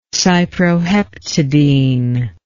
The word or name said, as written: cyproheptadine